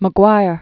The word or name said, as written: Mark David McGwire